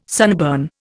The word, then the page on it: sunburn: Indian